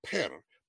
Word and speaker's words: per: So among the Egyptians, you have the word per, P-E-R, as we would pronounce it, but it's a picture of an enclosure.